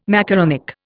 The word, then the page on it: macaronic: Indian